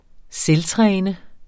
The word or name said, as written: selvtræne